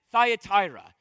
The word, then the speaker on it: Thyatira: So it's not Thyatira, it's a lot of people pronounce this place wrong. Not that it really matters, but it's Thyatira.